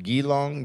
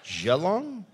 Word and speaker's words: Geelong: Geelong, Geelong, Geelong. Like the tea? Geelong. Geelong? Seriously? Two E's. Geelong.